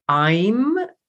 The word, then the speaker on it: Aim: And so the Bija mantra there is I'm, you can spell it A-I-M or A-Y-M, I'm.